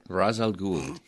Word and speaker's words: Ra's al Ghul: Ra's al Ghul. – I always would not want to say the S.